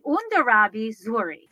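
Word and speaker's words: Hondarrabi Zuri: And it is Andarabi, Undarabi Zuri. Did I say that correctly, Rick? – Very, very close.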